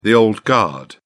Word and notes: old guard: British English

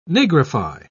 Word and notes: nigrify: British English

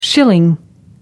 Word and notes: schilling: American English

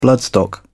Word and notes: bloodstock: British English

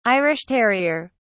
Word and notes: irish terrier: British English

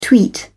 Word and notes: tweet: British English

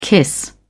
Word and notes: kiss: British English

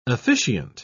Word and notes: officiant: British English

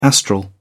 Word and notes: astral: British English

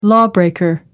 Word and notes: lawbreaker: American English

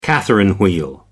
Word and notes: catherine wheel: American English

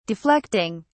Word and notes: deflecting: British English

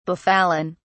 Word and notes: befallen: British English

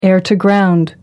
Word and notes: air-to-ground: American English

